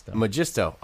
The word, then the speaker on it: Magisto: Replay, Magisto, is that how you say it? – Magisto.